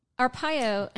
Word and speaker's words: Arpaio: So, Arpaio. Am I saying that right? – Mm-hmm.